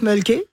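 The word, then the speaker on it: Mölkky: Ça, on prononce Mulky. – Ouais. – Et c'est M-O-L-K-K-Y.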